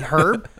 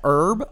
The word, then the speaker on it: herb: They got some really quality herb, which is, like, I think you're supposed to pronounce that herb, I hear. – No, but it's herb. Herb.